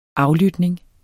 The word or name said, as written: AFLYTNING